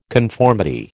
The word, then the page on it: conformity: American English